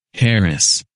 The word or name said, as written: Harris